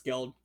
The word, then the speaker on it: Skeld: It's Skeld.